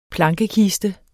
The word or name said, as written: plankekiste